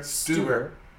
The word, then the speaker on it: Stuber: Yeah, you said that right. That's right. Stuber.